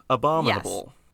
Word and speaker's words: abominable: Isn't that Abominable Snowman? Am I saying that right? – Yes. Abominable. Yes. Think of it like this. Sorry to cut you off, but, like, you know, you have a bomb, like a bomb that detonates, right, and blows people up, and put it in a bowl.